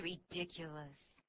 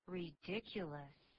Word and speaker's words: ridiculous: Don't be ridiculous. I'm not. – 这个对话最主要是常用的一个字，叫做荒谬的、可笑的。这个字怎么念呢？Ridiculous. Ridiculous. That's ridiculous. – That's ridiculous. 那真是荒谬的。这个字不太好念哦。我们碰到长音节字的时候啊，我的学习的方法是由后音节往前面这样念。